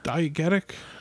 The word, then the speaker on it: diegetic: Diegetic? – Diegetic.